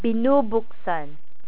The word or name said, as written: binubuksan